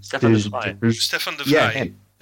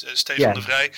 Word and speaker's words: Stefan de Vrij: I can't pronounce his last name. – Stefan de Vrij. It's Stefan de Vrij.